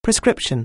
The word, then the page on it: prescription: anglais